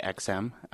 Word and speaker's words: AGSEM: Well, AGSEM, and we usually say XM, just pronounced phonetically, is a union.